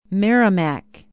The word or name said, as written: merrimac